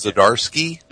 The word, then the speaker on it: Zdarsky: It's Zdarsky, right? – Zdarsky.